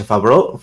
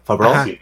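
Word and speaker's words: Favreau: Pero yo pero Disney, por ejemplo, en el ejemplo que pones de Mandalorian, este John, ¿cómo se pronuncia? Fabro, Fabro, Fabro Este John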